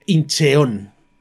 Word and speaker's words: Incheon: Inchon en la costa occidental coreana. Eh, ahora me dirán que no digo bien Inchon, hombre, realmente Incheon. ¿De acuerdo? Se escribe Incheon, yo siempre lo he llamado Inchon.